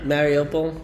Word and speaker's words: Mariupol: Mariupol.